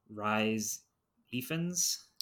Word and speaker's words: Rhys Ifans: It's Rhys Ifans.